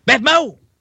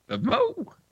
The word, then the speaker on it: BevMo: I feel like you need to pronounce BevMo as it's written. BevMo. – BevMo.